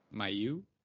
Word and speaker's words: Mailloux: How do you say his last name? – Mailloux. – I thought it was Mailloux, but I also thought that Corey Conacher was Corey Conacher for about half a decade, so.